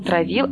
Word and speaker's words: травил: Травил, травил, травил. Это, наверное, газ какой-то. Газ травит. Э, вот у меня, по крайней мере, такая ассоциация. Ах, хлорное железо. Лисичкин мне помогает и пишет «травил». Ударение на первый слог.